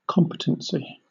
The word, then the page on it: competency: British English